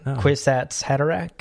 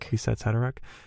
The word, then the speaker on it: Kwisatz Haderach: I feel like you nailed that. Kwisatz Haderach. – Kwisatz Haderach.